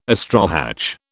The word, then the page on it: astrohatch: British English